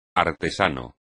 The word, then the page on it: artesano: espagnol